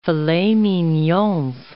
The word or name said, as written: filets mignons